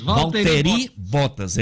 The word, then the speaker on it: Valtteri Bottas: Valtteri, Bottas. é esse mesmo, né? Do jeito que se escreve, então.